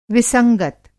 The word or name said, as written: विसंगत